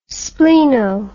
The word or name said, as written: spleno